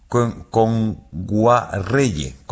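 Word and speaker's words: Kngwarreye: Emily Kame Enguayerre, lo he dicho mal. – Emily Kame. – Con Guarreye. – Emily Kame con Guarreye, sí.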